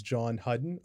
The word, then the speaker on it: Jon Hudon: Joining me today on the podcast for another great episode is Jon Hudon of Ocean Pacific Water Sports.